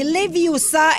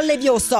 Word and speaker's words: Leviosa: C'est Leviosa et pas Leviosa.